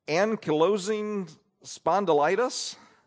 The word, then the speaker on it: ankylosing spondylitis: Ankylosing spondylitis.